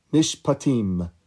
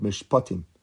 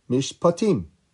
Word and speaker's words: Mishpatim: Did you hear how I said it? Mishpatim. Most people pronounce that word Mishpatim, but I want you to hear how it sounds according to the rules of Dikduk. Mishpatim.